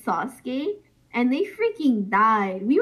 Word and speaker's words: Sasuke: Yeah, Sasuke.